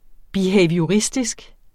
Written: BEHAVIORISTISK

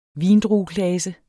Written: vindrueklase

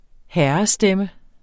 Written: herrestemme

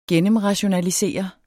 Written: gennemrationalisere

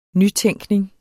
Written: nytænkning

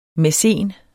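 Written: mæcen

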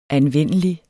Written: anvendelig

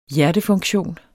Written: hjertefunktion

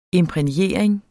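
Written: imprægnering

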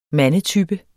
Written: mandetype

